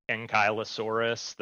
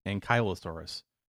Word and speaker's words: Ankylosaurus: Ankylosaurus. – Ankylosaurus. – Ankylosaurus. I figured I was probably pronouncing it wrong. – Oh, I don't know. That's how I guessed. You might be right. Ankylosaurus, like Kylo Ren.